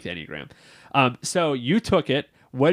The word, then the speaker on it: Enneagram: Which I can't pronounce. I might call it Enneagram or Enneagram. – Enneagram.